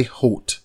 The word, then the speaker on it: Huot: Holt, and that's spelled H-U-O-T, 88, of Salix, Iowa, died Monday, April 20th, 2020.